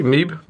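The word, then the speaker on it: Mibe: Uh, a handful of Honda executives led uh, by President uh, Toshihiro, uh, how do we pronounce this one? Maibi? Maybe, maybe, no, maybe, maybe sounds more Japanese. Maybe, sorry.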